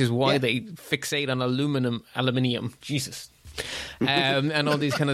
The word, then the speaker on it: aluminium: Because you said it right the first time. – Officially, actually, within the IEEE, you guys got sulfur spelt the sensible way and we got aluminium said the sensible way.